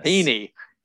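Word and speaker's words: Heaney: It may be even a depth type move like the Yankees made with Andrew, was it pronounced Haney? – Haney.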